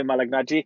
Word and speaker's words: Malignaggi: We're looking at Artem Lobov versus Paulie Malignaggi. Have I said that right? Paulie Malignaggi.